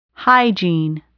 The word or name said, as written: hygiene